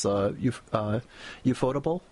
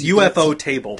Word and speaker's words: Ufotable: The production company, the animation company, is Ufotable. How's it pronounced? – I think that's what it is. I think that's how they do it. It's UFO Table, but I think they call it Ufotable.